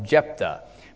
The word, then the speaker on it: Jephthah: This is the account of Jephthah. By the way, it's spelled J-E-P-H-T-H-A-H.